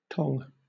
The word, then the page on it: tong: British English